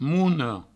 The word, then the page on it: mona: mouno